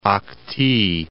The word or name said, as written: Akti